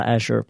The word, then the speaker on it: Azure: Och vanligtvis kommer jag nog att välja att uttala Azure på engelska,